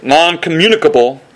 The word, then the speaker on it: non-communicable: Theologians call these His non-communicatable, that's not how you pronounce it, non-communicable attributes.